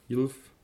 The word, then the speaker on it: ylf: Ähm, gut, wir haben ja in der Aussprache auch gelernt, dass ein Y am Wort Anfang ein J ist, also wie ein J ausgesprochen wird. Das verändert sich im Plural auch nicht, also es bleibt trotzdem ein Y. Ähm, haben wir zum Beispiel beim Wort für Fackel Jülf. Jülf also YLF,